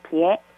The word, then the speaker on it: 别: 중국어에서 주로 뭐뭐 하지 마라, 금지를 나타내는 의미로 활용되는 한자 나누다 별 자는 중국어로 어떻게 발음할까요? 1번 시에, 2번 비에, 3번 지에, 4번 시에. – 리우. – 네. – 2번 비에. – 정답입니다.